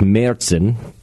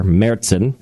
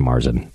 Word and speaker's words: Märzen: The next one on his list is Marzen, which this one kind of varies to, I believe in German it's Märzen, not with an A, but like an R, Märzen. Or in English, you could just say Marzen. Like, it's a Marzen.